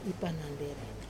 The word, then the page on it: panande: wamesa